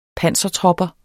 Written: pansertropper

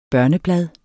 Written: børneblad

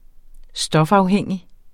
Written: stofafhængig

